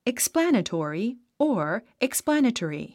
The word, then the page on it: explanatory: eksplǽnətɔ̀ri; エクスプラネトリィ